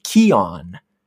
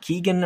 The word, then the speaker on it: Keoghan: The pronunciation I'm seeing is Barry Keoghan, not Keegan or Colgan. Keoghan.